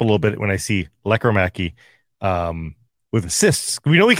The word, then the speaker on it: Lekkerimäki: Also, E&O for us both, or for the entire market, we're finding out Lekkerimäki, not Lekkerimäki. Accent on the first syllable now is what it seems to be. Lekkerimäki.